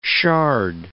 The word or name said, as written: shard